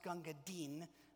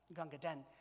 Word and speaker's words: Gunga Din: It's actually pronounced Gunga Din, but it's D-I-N, and so people call it Gunga Din.